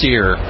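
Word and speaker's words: SERE: And now people have probably heard this pronounced as SEER. It's S-E-R-E.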